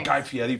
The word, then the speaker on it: Guy Fieri: Guy was, hey, let's talk about Guy Fieri for a second.